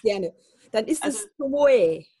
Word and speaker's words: Tomoe: Also wenn es japanisch ist, wird es aber Tomoe ausgesprochen.